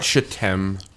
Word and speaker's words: shittim: It is shittim. It is. The worst part is there's no other type of pronunciation.